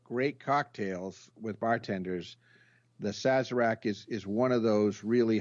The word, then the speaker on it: Sazerac: The Sazerac, yes.